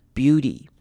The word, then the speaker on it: beauty: 好， beauty 这个字的 T 也是一样要变成 D 的声音 beauty 那个要发出的 beauty。 You're kind of a heavenly blessed beauty。